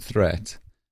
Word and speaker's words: threat: Watch out for meat and grate and threat. They rhyme with sweet and straight and debt.